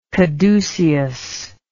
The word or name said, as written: caducean